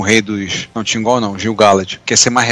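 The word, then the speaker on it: Gil-galad: cada nome. É Gil Galad.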